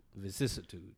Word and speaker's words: vicissitudes: Because it was two things you said, one about Shades of Shadows and Vestitudes. – It's called like the Vicissitude. – Vicissitudes. – Yeah. – Sorry, mispronunciation. Vicissitudes.